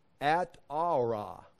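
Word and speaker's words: Atarah: This is not saying, we can see the Hebrew, I've got it in a box up there right above it, is At-Ara. It's spelled A-T-A-R-A-H. I'm going to double check that. A-T-A-R, oh my gosh, I spelled it right. That is, he already has a Nezer. This is an Atera, and it means a crown or a wreath.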